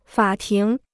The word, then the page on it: 法庭: fǎ tíng